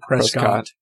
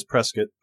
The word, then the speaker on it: Prescott: That must be a local or regional pronunciation. I always say Prescott. – I have no idea. I was four when we left, so. – It is Prescott.